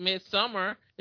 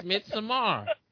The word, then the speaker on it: Midsommar: That's how it's pronounced. It's not called Midsommar. It's Midsommar.